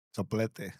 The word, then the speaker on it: soplete: There was, you know, patches like the Timu, how do you say that? Soplite? – Soplete. – Soplete.